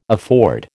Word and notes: afford: American English

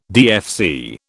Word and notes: DFC: British English

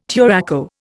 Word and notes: turaco: Indian

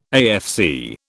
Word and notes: AFC: British English

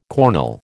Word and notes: cornel: American English

